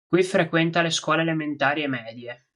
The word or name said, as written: elementari